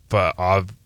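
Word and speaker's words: Fa'avae: Is it Fave or Fa'ave? – Fa'ave. I struggle with it. Like, I have the pronunciation. – It is Fave? – And I struggle with it. – Okay. Because I always thought it was Fave, and then it's F-A apostrophe. – It's separated into three different things.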